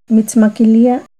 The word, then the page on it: Mitzmaquilia: Náhuatl